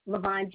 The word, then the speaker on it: Lavonne: Levon Jackson. – Can you spell that first name for me? – L-A-V-O-N-N-E.